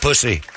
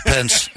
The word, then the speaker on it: Pence: Oh, great, it's Mike Pussy. – It's pronounced Pence, actually.